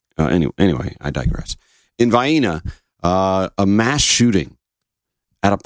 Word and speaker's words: Vienna: Not Vienna. Vienna. We don't pronounce these towns exactly the way they would be pronounced where they are.